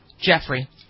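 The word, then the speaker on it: Giuffre: Is it Griffin? How do you pronounce it? – Jeffrey.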